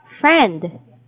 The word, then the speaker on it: friend: Friend. – Friend. – Okay, please take note of the vowel sound. So it's E, okay?